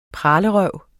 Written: pralerøv

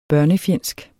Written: børnefjendsk

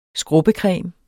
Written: skrubbecreme

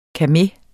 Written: KAME

